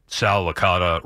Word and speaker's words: Licata: And then, like, the first time I worked with him, did anybody ever tell you that Sal Licata rhymes with veal piccata?